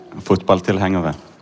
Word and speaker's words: fotballtilhengere: Fußballfan, wie spricht man das aus? – Fotballtilhengere.